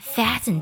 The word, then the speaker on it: fasten: Fasten your seat belt。要特别注意啊，我们这里呢，字母 T 在 fasten 当中是不发音的。